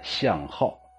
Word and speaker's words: 向滈: 首先呢，我们来说一下这个作者的读音呢，向浩，浩啊，左边一个三点水，右边一个高，这个字有两个读音，一个念浩，一个念血。